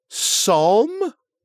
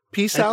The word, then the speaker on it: psalm: It is the 22nd psalm? Psalm? P-psalm? – I think the P is silent.